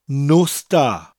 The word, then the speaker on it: nos da: Now, it sounds like I'm saying there are no stars in the sky. Nos da. But it's spelt N-O-S, nos, followed by da.